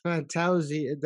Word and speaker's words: Fantozzi: Did I say that right? – Yes, you did. – I got the Italian right. – Yeah, there's a few ways to pronounce it, and you got the way we say it. So we say Fantozzi.